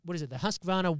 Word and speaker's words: Husqvarna: But this week, the good folks at Husqvarna. Is that how you pronounce it? – Husqvarna Chainsaws. Husqvarna.